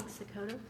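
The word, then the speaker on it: Cikota: Kathy King, Sakota, 101 Kingsbridge Road, Rehoboth Beach, Delaware.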